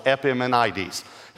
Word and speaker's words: Epimenides: He's quoting Epimenides. It's five syllables, Epimenides.